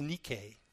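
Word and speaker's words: Nike (νίκη): In the Greek, it's pronounced a little different. It's Nike.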